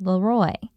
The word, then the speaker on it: Leroy: It's worth noting here that Governor Collins' first name should in fact be pronounced Leroy.